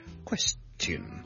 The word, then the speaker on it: question: 如果大家有同一啲老一辈嘅英国人沟通咧，你有时可能会听过呢一个发音，听住就系 question 啊。咁呢个系一个比较非常之老式啲嘅英文发音，而家就比较少啲人。如果你尤其是你个后生嘅人咧，就会比较少啲咁讲，但系亦都系有人会咁读嘅，或者教育程度比较高嘅老一辈嘅英国人咧，有可能会听到 question。